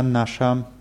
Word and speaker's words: наше: Die Betonung ist auf A. Наше, ваше. Наша, ваша, наши, ваши. Ganz einfach zu merken, weil die Betonung immer auf dem ersten A ist.